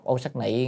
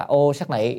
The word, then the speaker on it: ô sắc nị: Phạm âm ô sắc nhị hay là ô sắc nhị cũng được, chữ này đọc nhị hay nị cũng được, thì chàng thấy đọc ô sắc nhị nghe hay hơn. Tiếng Phạn á, ở trong tiếng Phạn người ta sẽ đọc là ô sắc nị, tức là ô sắc nị trong tiếng Phạn.